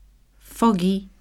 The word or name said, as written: foggy